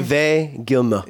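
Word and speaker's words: Yves Guillemot: Yvette Gilmour. – What? How do you say his name? – Yvette Gilmour.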